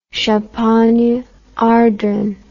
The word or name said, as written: Champagne-Ardenne